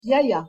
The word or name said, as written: yaya